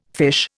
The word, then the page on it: fiche: Indian